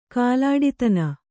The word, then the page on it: ಕಾಲಾಡಿತನ: kālāḍitana